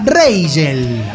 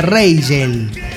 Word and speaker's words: Reigel: Vamos a escuchar a continuación a una banda llamada Reigel, así como se escribe, Reigel.